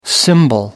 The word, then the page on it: cymbal: American English